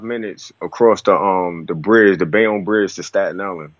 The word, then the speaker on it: Bayonne: but it's Bayonne. – It's Bayonne, Bayonne, yeah.